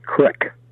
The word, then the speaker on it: creek: and the word creek in Nebraska is pronounced crick. – Yep. – Crick, C-R-I-C-K.